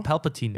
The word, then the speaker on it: Palpatine: Wird im Englischen Palpatine ausgesprochen? Nee, Palpatine. – Immer Palpatine. Palpatine. Palpatine. Ja. Das, ist Betonung also, liegt auf dem L.